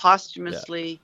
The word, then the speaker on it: posthumously: I see the word in my brain and it's not pronounced like it's spelled, but Yeah. – posthumously, the family of the late David Rutterham.